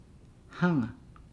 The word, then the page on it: hanga: Nukuoro